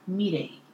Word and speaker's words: Mirei: Ooh, if you want to really get technical with my Japanese pronunciation, it's Mirei.